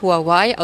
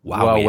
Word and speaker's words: Huawei: Sie springt nicht nur da ein, wo der chinesische Konkurrent Huawei aus Sicherheitsgründen boykottiert wird. Sie verdient auch kräftig mit der Mobilfunktochter viel Geld. – Guck, sie sagt auch Huawei. Mhm. Also können wir das auch sagen. – Huawei, Huawei.